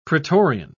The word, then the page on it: pretorian: British English